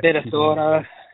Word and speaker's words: Minnesota: Minnesota. Oh, that it's a long O.